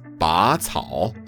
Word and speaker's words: 拔草: 那我拔草了。Hey, I just realized that I pronounced 拔草 wrong the whole time. It's not 拔草, it's 拔草, the second tone.